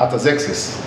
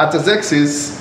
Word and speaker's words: Artaxerxes: the cupbearer to the king Artaxerxes. That's how you pronounce it. So I uh, had to check that up. um, right, Artaxerxes um, was the king of Persia.